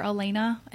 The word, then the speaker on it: Alayna: Well, like I said, I'm definitely on Spotify and iTunes under Elena, and my name is spelled A-L-A-Y-N-A, so it's a little different.